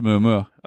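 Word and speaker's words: muumuu: Could be, but it's pronounced a Muamua.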